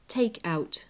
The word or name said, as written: take out